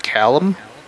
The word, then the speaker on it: Callum: How do you even pronounce that? Callum? – Yeah, like Callum Keith Rennie.